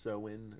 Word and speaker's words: Samhain: I think it's actually pronounced Sowin or something.